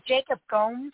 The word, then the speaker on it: Gomes: And is it Jacob Gomes? Is that how you pronounce his name? – Yeah, Jacob Gomes.